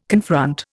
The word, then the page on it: confront: Indian